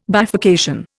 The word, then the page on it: bifurcation: Indian